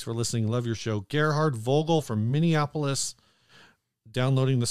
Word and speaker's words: Gerhard: He says, pronounced it like Gary Hard without the Y. So that's Gerhard.